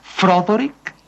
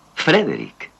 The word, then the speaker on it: Frederick: ¿Dice usted también Froderick? – No, Frederick. – ¿Y por qué no es Froderick Frankenstein? – ¿Por qué no? Es Frederick Frankenstein.